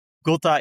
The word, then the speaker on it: Gota: Gota is Spaans voor druppel, dus het wordt vaak op zijn Spaans uitgesproken. Gota is een investeringsgroep dat investeert in startups in de voedingsindustrie.